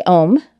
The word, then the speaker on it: om: I'm going to do a holy om, O-M, but when it is pronounced in one long syllable, we actually pronounce all three of the sounds, which is ah, oh, and then the I am.